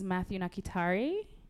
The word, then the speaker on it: Mathew Nakitare: Is Mathew Nakitare.